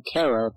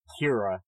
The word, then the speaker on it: Kara: And that's what really made that show interesting, especially in season one, was seeing Kara, the person, deal with having an alter ego now that... – Excuse me, her name is pronounced Kira.